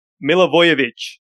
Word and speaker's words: Milivojevic: I've got to pronounce the name correctly. Otherwise I have to transfer it out, apparently. So Milivojevic. – There we go.